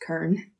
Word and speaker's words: Kern: I do want to say at the beginning, it really bugs me that I say her name Leslie Kern because it's Can, which means seed in German. But she's Canadian, so I guess it's pronounced Kern.